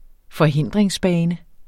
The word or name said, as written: forhindringsbane